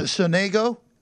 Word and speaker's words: Sonego: They pronounce it, for whatever reason, they pronounce it Sonego, which I have no idea why they pronounce it that way. I would think it's Sonego, too, but everybody pronounces it Sonego, so it's against the Italian, Lorenzo Sonego.